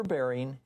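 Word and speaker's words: Berine: Use the best source for your heart's health, Super Bearing Advanced Cholesterol Support.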